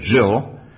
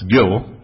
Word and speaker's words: Gill: Now I'm seeing some nodding faces, because sometimes I said Jill. That's I, as a German, would say it, but it's Gill, apparently.